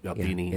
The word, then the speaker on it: Albini: Albini, yeah.